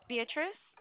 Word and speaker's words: Beatriz: Yes, Beatrice. B-E-A-T-R-I-Z. – B-E-A-T-R. – B-E-A-T-R-I-Z.